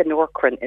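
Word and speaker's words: Nourkrin: And then in terms of the hair, something that's safe, I think the Norcrin is brilliant.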